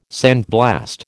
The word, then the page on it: sandblast: American English